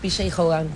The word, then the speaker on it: P.J. Hogan: La segunda película que seleccionó acá la señorita es El Casamiento de Muriel, Muriel's Wedding, una película australiana dirigida por P.J. Hogan, ¿se pronunciará? – Sí, P.J. Hogan.